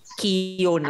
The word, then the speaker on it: Keoghan: Keoghan daw. Keoghan ang pronunciation niya.